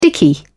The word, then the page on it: dicky: British English